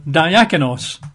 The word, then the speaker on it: diakonos: You'd spell that in English letters as D-I-A-K-O-N-O-S, diakonos, diakonos.